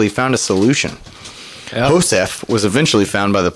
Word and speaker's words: Josef: The man identified as Philip, I want to say Joseph, but I want to say Joseph. – Jose? – But it's spelled J-O-S-E-F, so I'm pretty sure it's Philippe Josef.